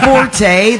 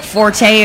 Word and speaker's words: forte: Well, isn't forte, that's your forte, isn't that fort? – Fort, yes.